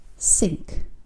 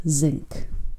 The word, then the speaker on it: sink: Number one, the other word for basin is pronounced sink and not zinc.